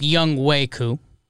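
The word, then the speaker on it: Younghoe Koo: I made a young ho joke last episode because I'm a tasteless person. It is pronounced Young Weku.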